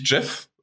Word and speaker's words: Geoff: Aber na gut, dann kommen wir erstmal zu den Game Awards 2020 und wie wir gelernt haben, äh, wird Geoff Keighley tatsächlich Jeff äh, ausgesprochen, weil er hat das tatsächlich selbst gesagt. Echt? Und ja, er hat es selbst gesagt und hat Jeff gesagt.